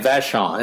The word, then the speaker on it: Vachon: His full name is Rogatin Vachon, another French name.